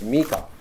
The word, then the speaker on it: Micah: Now, Micah is a name that's properly pronounced Mika.